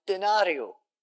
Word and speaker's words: denarius: And a denarius, unsurprisingly, is pronounced denario.